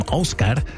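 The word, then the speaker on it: Ásgeir: Der Mann kommt aus Island, deswegen wird er auch so ausgesprochen, Ósgeir.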